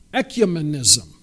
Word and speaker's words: ecumenism: So then you've got this other word that is properly pronounced, ecumenism. Ekya, I looked it up.